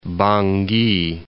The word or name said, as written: Bangui